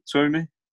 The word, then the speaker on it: Twomey: Is it Twomey? – Twomey. – Liam Twomey from The Athletic. – If in doubt, yeah. Just think Chuckle Brothers to me to you.